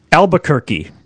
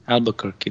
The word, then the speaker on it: Albuquerque: Albuquerque. – Albuquerque.